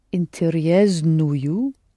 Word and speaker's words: интересную: Я читаю интересную книгу. – All right. Will you pronounce интересную? – Интересную. – Right. S there.